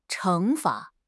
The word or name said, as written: 惩罚